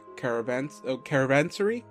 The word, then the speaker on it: caravansary: No, I actually looked it up. Okay, when I look it up, it's written down as caravansary. I think that's how you pronounce it.